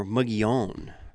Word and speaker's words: Mogollon: The Mogollon Rim, they say, is probably one of the most mispronounced locations in Arizona. I say Mogollon. Locals pronounce it more Mogollon.